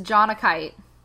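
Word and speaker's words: Jonigkeit: I finally figured out how to say his last name when I was tagging him in the Instagram post. It's Jonakite. – Oh, okay, Jonakite. – Because that's literally what his Instagram handle is John A. Kite.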